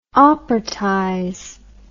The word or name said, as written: operatize